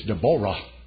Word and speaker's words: Deborah: Deborah is a pretty name. A lot of women named Deborah, but in Hebrew it's Deborah because the accent's on the Ah, Jehovah, the name's attached to it.